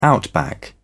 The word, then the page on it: outback: British English